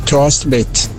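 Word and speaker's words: Thorstvedt: Il giocatore Sassuolo accostato alla Fiorentina si chiama, ed è la pronuncia credo corretta, Torsted. Danese e norvegese hanno una pronuncia molto simile. Bene. Quindi è Tosvet.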